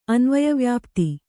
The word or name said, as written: ಅನ್ವಯವ್ಯಾಪ್ತಿ